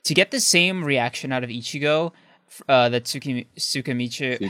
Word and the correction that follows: Tsukishima: how do you say it again? Sorry. Tsukishima.